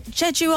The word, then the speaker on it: Jeju: How do you spell that, Pip? – J-E... – Jeju Island. – J-U. – Oh, yeah, Jeju. I've got it.